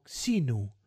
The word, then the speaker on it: Xenu: No sé cómo se pronuncia en español, pero en inglés lo pronuncian como Xenu.